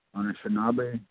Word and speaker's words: Anishinaabe: Anishinaabe, it's pronounced Anishinaabe, but then you go to another person there, they pronounce Anishinaabe, they put a another abbreviation because it comes from farther up north.